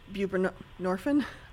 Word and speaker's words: buprenorphine: Well, and before you respond, David, I would add to that I know this isn't buprenorphine.